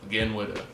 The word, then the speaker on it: Agenwida: He's got a song called Again Witta, and it's how it's spelled, AGENWIDA. Again Witta.